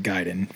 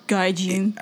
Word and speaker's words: Gaiden: I was going to say, no, it is pronounced perfectly for all of the American audience in the seminal film The Wizard at the end, where he's like, what are they playing first? And the guy goes, Ninja Gaiden. Hoi! And like, seriously, he does that. It's the best. That's where the official pronunciation comes from in my mind.